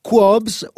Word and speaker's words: Kwabs: Sì, questo signore si chiama Qobs, scritto K-W-A-B-S, la pronuncia esatta dovrebbe essere Qobs, un cantante favoloso che ha fatto varie esperienze.